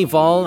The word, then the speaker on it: Hval: This is Ginny Vol, and that last name is spelled H-V-A-L. Ginny Vol, she's a singer from Norway.